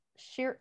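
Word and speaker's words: Scheierl: Can you spell it for me? – It's S C H E I E R L. – Cheryl. – Cheryl. That sounds right.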